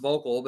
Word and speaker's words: Vuukle: It's pronounced Vocal, but it's spelled V-U-U-K-L-E.